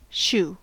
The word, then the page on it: shoe: American English